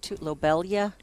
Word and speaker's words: lobelia: You already you're getting some lobelia. Am I saying that right? – Nope. – Lobelia.